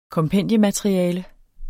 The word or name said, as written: kompendiemateriale